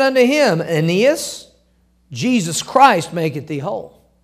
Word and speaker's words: Aeneas: And there he found a certain man named, and the way you pronounce that is Aeneas.